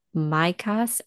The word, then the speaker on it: Mychas: It's Christina Mikas, and it's her last name is spelled Mychas.